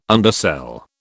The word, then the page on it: undersell: British English